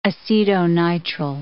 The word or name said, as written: acetonitrile